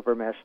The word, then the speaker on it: Vermes: It's 1995, the Dead Sea Scrolls in English, Geza Vermesh, and the last name there is Vermes, pronounced like SH.